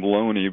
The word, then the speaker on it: Looney: This Earl of Oxford theory started in 1920 by a guy, his last name, well, he pronounced his last name Loney, but it's spelled Looney.